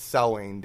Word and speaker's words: Samhain: And before that, actually, that's Samhain Dance, by the way.